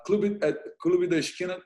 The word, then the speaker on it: Clube da Esquina: Clube da Esquina, tudo que você podia ser, which means everything you could be.